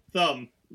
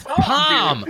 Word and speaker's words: Thom: T-H-O-M. How's that pronounced? – That's just Tom. – That's fucking Tom.